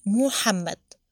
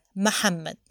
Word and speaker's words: محمد: محمد بتنطق بضم الميم فبتكون محمد، وأجيز أن احنا نفتح الميم للسهولة والتيسير، محمد. يبقى النطق عندنا محمد ومحمد.